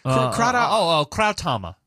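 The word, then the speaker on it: Krauthammer: How do you say this guy's name? Kratahammer? – Krautama.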